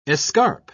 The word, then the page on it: escarp: British English